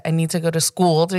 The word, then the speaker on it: Skool-Ed: We pronounce it Skool-Ed.